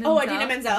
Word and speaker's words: Idina Menzel: Idina Menzel.